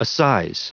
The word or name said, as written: assize